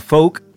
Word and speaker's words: PHOAC: Estáis, acabáis de escuchar el nuevo single de FOAC, que antes he dicho FOAC, se pronuncia FOAC, P-H-O-A-C, que ahora nos explicará qué significan las siglas, para el que no lo sepa.